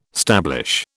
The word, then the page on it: stablish: British English